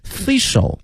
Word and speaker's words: facial: Facial mask, facial, F-A-C-I-A-L, 跟我们说这个 face, 啊有一定联系哈，但是呢比这个 face, 这个字母呢要略微多几个哈，用它的发音呢也发生了一个小小的变化，从这个 face 变成了 facial,